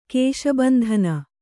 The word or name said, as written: ಕೇಶಬಂಧನ